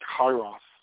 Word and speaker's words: kairos: That word is kairos. That word is spelled K-A-I-R-O-S, kairos.